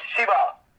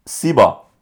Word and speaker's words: C-ball: Sheball. – What is it? – Sheball. – Do you have to say it like that? – It's C-ball. – C-ball.